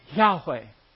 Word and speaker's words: Yahweh: Y-H-W-H. And it's pronounced Yahweh. At least we think that's how you pronounce it.